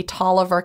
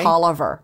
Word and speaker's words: Taliaferro: You ever been to Tolliver County? – I have not. – If you go down I-20, you'll see Tolliver County, but it looks like Taliaferro. – Oh, gosh. – But you say Tolliver.